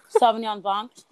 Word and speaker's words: Sauvignon Blanc: It's like this Sauvignon Blanc.